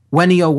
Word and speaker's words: venio: There's really not a V sound in classical Latin. It's pronounced with a W. So it's V-E-N-I-O is the verb, and it's pronounced venio, venio, veniere, ventus. And that means to come or to become present, to arrive.